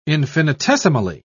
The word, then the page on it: infinitesimally: British English